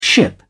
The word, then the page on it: ship: British English